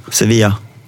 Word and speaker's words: Sevilla: Hur säger man det på så här om man ska spanska uttala det? – Sevilla.